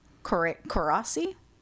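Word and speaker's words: Coraci: All right, it was directed by Frank Carracci. It's mm-hmm. a hard C.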